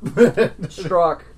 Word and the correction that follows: Stroik: Stroik? Stroik. – Stroik. – That's how we pronounce it. Yeah, Stroik.